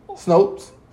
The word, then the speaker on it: Snopes: Why can't I own a Canadian spawns? – Hmm? Spell it. – S-N-O-P-E-S? Spawns? – S-N? – Snons? Yeah. Snopes? – Snopes?